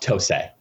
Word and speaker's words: Tose: Which is essentially the PSP version, which came out in 2008, and that was ported by Tosi. Did I pronounce that right? Did anyone see Tose? Yeah, I don't know how you say it. – Tose. I believe it's pronounced Tose.